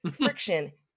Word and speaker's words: Frixion: The Pilot pens are called, it's called Friction, but it's spelled funny. It's Friction. Frixion.